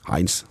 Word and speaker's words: Heinz: Du har egentligen varit en hard tysk uttale på Heinz. – Heinz. – Heinz. Ja, han kommer ju inte från England.